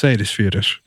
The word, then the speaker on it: Seyðisfjörður: And I don't know if you got that pronunciation, but it's Seyðisfjörður.